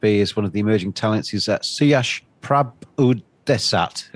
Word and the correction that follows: Prabhudesai: Have I pronounced that properly? – Yeah, Suyash Prabhudesai.